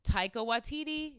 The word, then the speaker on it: Taika Waititi: In the new Taika Waititi, is that how you say his name? – Yeah.